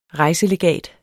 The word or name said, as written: REJSELEGAT